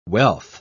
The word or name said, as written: wealth